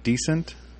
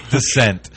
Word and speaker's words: Descent: Or is it Decent? – It's Descent. – That was pretty decent pronunciation of Descent. – OS 3.0. – As in descending, not descent.